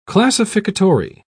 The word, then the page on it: classificatory: British English